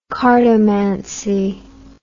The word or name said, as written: cartomancy